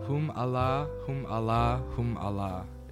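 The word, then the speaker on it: Hum-Allah-Hum-Allah-Hum-Allah: And how do you say the name of this track? – Hum Allah, Hum Allah, Hum Allah.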